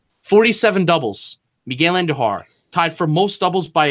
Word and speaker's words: Andujar: Roll your R's when you say Andujar. Miguel Andujar.